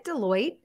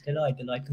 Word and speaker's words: Deloitte: Is it Deloitte? Is that how we say the company name? – Yeah. You're absolutely right. It's Deloitte Consulting.